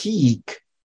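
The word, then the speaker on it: hic: The long I is the sound you get in the word sleep. And the word we have here is heek. Heek meaning here.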